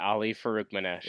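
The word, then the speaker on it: Ali Farokhmanesh: I'm going to butcher his name. Ali Farouk-Manash, I think, was the Northern Iowa star. – Yeah, Ali Farouk-Manash.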